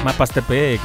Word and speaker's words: Mapastepec: Saluditos a todos los que están sintonizándonos a la hora de la O por allá en Mazamana, Mazamazana, Mazapritepiqui. Mapastepec, ya lo dije bien.